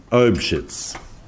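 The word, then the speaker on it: Eybeschütz: There's different ways of spelling this, but it's basically Obshitz. Obshitz. It's something along those lines. Obshitz. It's usually spelled E-U, or it's pronounced Obshitz.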